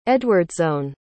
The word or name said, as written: eadwardsone